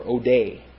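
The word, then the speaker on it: ode (ᾠδή): Each of those verses not only has the verb form ado, but also has the noun form ode, or ode, accent on the last syllable. All right, so we got ado. Next we have katharizo. Not katharizo, kitharizo. Kitharizo. K-I-T-H-A-R-I-Z-O.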